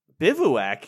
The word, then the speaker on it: bivouac: Let's figure out how to say this. Bivouac? No, you're correct.